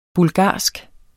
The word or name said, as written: BULGARSKE